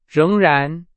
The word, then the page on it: 仍然: Chinese